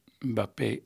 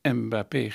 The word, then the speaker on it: Mbappé: Het is opvallend hoe Nederlandse voetbalverslaggevers Bappé uitspreken. Telkens wordt nadrukkelijk Mbappé gezegd. In Afrika beginnen veel namen met een N of een M.